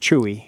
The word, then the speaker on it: Chuy: Or is it pronounced C-H-U-Y? Is it pronounced Chewy? – Yes, pronounced Chewy.